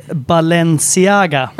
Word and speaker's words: Balenciaga: Du har en väska bakom dig som det står Balenciaga.